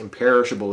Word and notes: imperishable: American English

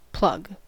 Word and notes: plug: American English

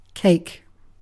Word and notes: cake: British English

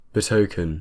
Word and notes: betoken: British English

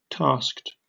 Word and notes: tasked: British English

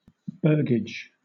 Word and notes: burgage: British English

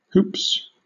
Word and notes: hoops: British English

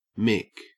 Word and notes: mick: Australian English

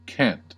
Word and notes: cant: American English

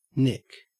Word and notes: nick: Australian English

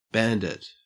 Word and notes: bandit: Australian English

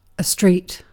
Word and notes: street: British English

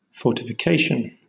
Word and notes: fortification: British English

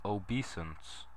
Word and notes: obeisance: American English

